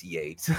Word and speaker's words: Yeats: The first being, oh, wait, it's Yates, not Yeats. – Right. – I'm talking to an artist and, well, you know, like W.B. Yeats up there. It's Yates.